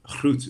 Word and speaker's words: Gruut: called Groot, or Groot is how they pronounce it, G-R-U-U-T, and it's an all-Groot brewery.